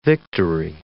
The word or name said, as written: victory